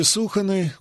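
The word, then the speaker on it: Сухона: А в названии реки Суханы ударение нужно делать на первом слоге.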